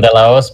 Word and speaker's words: De La Hoz: Yes, the H is silent, De La Hoz, but you got pretty close. – De La Hoz. Okay, De La Hoz.